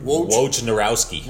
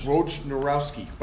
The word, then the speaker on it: Wojnarowski: So the rumor is they offered 4 first rounders, but this was reported by Adrian Wojnarowski. Did I say his name? – Wojnarowski. – Wojnarowski.